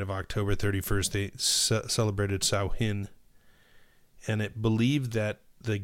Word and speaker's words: Samhain: Or how they pronounced it is Samhain.